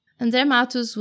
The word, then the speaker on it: André Matos: And, by the way, I'm going to be pronouncing his name and the names of his bands with the Portuguese, Brazilian Portuguese pronunciation. I hope that's not a problem for you. André Matos was one of the great names of heavy metal, or power metal, if you want to be specific.